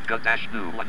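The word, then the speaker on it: GNU: New is a recursive acronym for quote news not Unix quote : it is pronounced gut-new.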